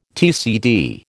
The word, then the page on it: TCD: American English